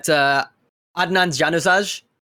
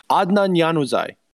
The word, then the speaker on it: Januzaj: Oh, you got Adnan Januzaj. – I literally just said his name. You've already horrifically mispronounced it. Adnan Januzaj.